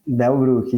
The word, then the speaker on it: Bellbruck: E aí, quando você recebeu o convite da Belbrook, tá certa a pronúncia? – Belbrook, isso aí.